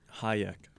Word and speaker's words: Hajek: Libor Hadshek, I'm pretty sure that's how it's pronounced. – Mm-mm. Scored no. What? Hajek. Hajek.